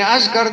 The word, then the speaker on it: أذكر: محمد ابن حاتم ابن أذكر، أذكر ألف زال كافرة ابن أذكر، وممكن تبقى أذكر لكن أنا ما عنديش ضبط فيها، فاللي يضمها ماشي، اللي يفتحها اللي يكسرها، أنتوا أئمة طبعا. الفرشخي لما ترجم لابن أذكر